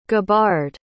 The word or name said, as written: gabbard